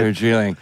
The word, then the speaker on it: Darjeeling: Darjeeling.